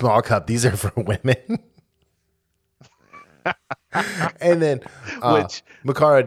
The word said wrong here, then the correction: Mariko: didn't how do you say her name? Mariko, sorry.